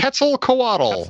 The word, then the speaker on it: Quetzalcoatl: Quixicolic? Is that the proper pronunciation? – Quetzalcoatl. – Quetzalcoatl. – Thank you.